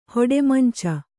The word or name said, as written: ಹೊಡೆಮಂಚ